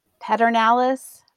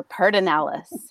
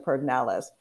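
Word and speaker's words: Pedernales: Paternalis or Paternalis, and why? And I have a feeling LBJ and Willie Nelson may factor into this story. – Yeah, no, I very much resist the LBJ pronunciation of Pardinales.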